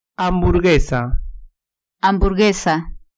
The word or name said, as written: hamburguesa